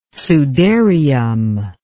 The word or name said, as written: sudarium